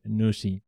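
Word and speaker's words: Nusii: It's spelled N-U-S and double I, Nusi.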